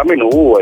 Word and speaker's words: menù: Il termine viene usato in italiano a seguito dell'adattamento della parola francese menù senza l'accento, ma noi lo pronunciamo accentato. Prima di essere utilizzato in italiano, la parola menù è stata oggetto di un referendum tra esperti gastronomi dell'Ottocento e alla fine è entrata nell'uso comune.